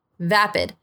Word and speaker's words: vapid: Vapid. Vapid. B corta A P I D. Vapid. Pero según Merriam-Webster se puede decir vapid o vapid. Entonces, las dos pronunciaciones están correctas.